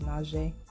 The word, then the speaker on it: nage: I didn't teach you that, but I just like saying nage because the little French soft G. The soft French G is nage. J-j-j-j.